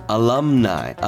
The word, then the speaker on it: alumni: アルムナイ。 – アルムナイ。 – あですね。あ、 A L U M N I の U をあっていうふうに発音するんですよ、英語では。あ、え、こっち U.S. 英語では。